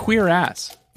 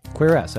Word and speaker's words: cuirass: Here we go. Pronunciation guide. Mm-hmm. Cuirass. – Cuirass. I was right.